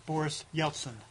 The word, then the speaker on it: Boris Yeltsin: Boris Yeltsin.